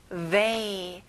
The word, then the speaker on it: They: They. 앞에 TH 발음을 잘해주세요.